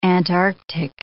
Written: Antarctic

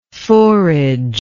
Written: forager

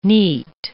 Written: neat

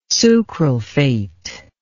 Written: sucralfate

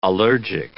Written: allergic

